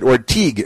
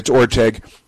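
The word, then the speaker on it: Orteig: So and again, they mispronounced it Orteg. It's Orteg, but that's okay.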